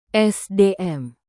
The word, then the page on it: sdm: indonesian